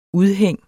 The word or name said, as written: udhæng